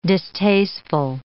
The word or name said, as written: distasteful